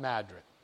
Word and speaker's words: Madrid: It looks like it's spelled Madrid, but it's pronounced Madrid.